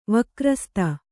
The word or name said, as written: ವಕ್ರಸ್ತ